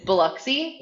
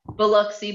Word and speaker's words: Biloxi: It's Biloxi. – Yes. – Biloxi Blues is what I watched, and I liked it.